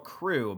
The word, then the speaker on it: krewe: But there's also, like, every different, they're called crew, but it's spelled K-R-E-W-E.